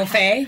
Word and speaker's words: Rofe: Rofe. Rofe. I'm, I'm not gonna, sure if I'm saying his name right. I'm sorry. R O F E with a little apostrophe on the end. – Do you want Rofe? Have, Rofe. Okay, I'm gonna go with that.